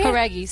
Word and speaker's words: Horaghi: Did I spell pronounce it right? – Horaghi, sim.